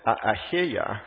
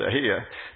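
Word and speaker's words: Ahijah: Indeed, Abiyah, the prophet, Ahiyah, again, that's how you pronounce it, Ahiyah, the prophet is there, who told me that I would be king over this people.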